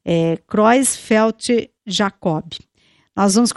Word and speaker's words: Creutzfeldt-Jakob: É, Creutzfeldt-Jakob, tanto faz.